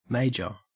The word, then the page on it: major: Australian English